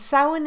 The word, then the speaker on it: Samhain: But if we concentrate on Samhain itself, okay, and Samhain is spelled S-A-M-H-A-I-N, for those of you who aren't Irish, okay?